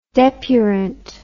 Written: depurant

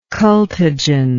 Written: cultigen

